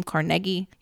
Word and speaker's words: Carnegie: It always led to a really lively debate over whether individuals like Andrew Carnegie, and that's how you pronounce his name, Carnegie, he was a Scottish immigrant, John D.